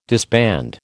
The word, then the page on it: disband: American English